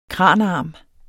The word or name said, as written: kranarm